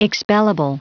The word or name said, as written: expellable